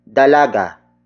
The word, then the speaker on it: dalaga: That would be dalaga. Dalaga. Dalaga. Dalaga is spelled as D-A-L-A-G-A.